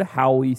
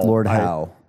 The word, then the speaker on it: Howe: Kyle, pull up the Lord Howie stick insect or Howe. – Lord Howe. – I always say Howie because of how it's spelled.